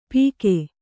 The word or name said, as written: ಪೀಕೆ